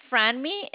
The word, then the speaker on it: Franmi: My name is Franmi. It's spelled F as in Frank, R-A-N-M-I. It's like Franmi. – Frank. Yeah, it's like Frank, but without the K.